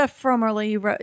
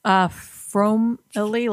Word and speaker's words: Fromerly: It's the Fromerly. You said that right. Fromerly, like family, but foam.